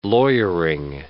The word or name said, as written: lawyering